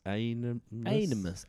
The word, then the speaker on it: Aenimus: A-E-N-I-M-U-S or Animus? Animus? – Animus.